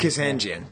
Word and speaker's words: Kazanjian: And then there is an interview with Howard how do you pronounce his name? Kazajian? – Kazanjian.